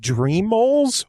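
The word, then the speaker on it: Dreamals: I don't know if it's Dreamoles or Dreamoles. It's spelled Dream, A-L-S. Dreamoles. – Dreamoles? – It's Dr. Eamles.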